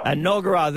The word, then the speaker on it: Enoggera: Today's winner, uh, and he's on the line, is from, uh, and I hope I'm pronouncing it correctly, in Queensland, Enoggera, Enoggera. G'day. Enoggera. Enoggera, there you go.